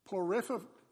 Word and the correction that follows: Porphyry: I think you pronounced it Porphyry. There you go. Porphyry.